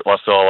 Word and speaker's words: Wasilla: Is it Wasilla? Did I say that right? – That's right. Wasilla, Alaska.